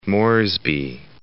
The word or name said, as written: South Moresby National Park